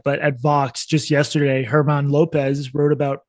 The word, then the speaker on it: German: His name is spelled German, Herman Lopez, he's honest.